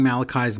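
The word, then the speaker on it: Malakai: And I wish Taz would stop freaking saying Malachi's name wrong. I wish he would just say Malachi. Malachi!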